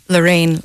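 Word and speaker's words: Larena: It's L-A-R-E-N-A, did I pronounce that right? – So it's Lorraine, like Lorraine with an A. Yeah, Lorraine. – Lorraine. – But Lorraine. It's spelled funny, you see, so that's the problem.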